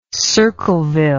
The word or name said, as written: Circleville